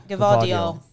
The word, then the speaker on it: Gvardiol: Gvardiol.